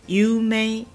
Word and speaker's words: 有名: 还有像是 “you may”，好，这也是有名的，嗯，“you” 是有，然后 “may” 是那个名的读音，嗯，嗯那。